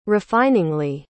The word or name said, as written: refiningly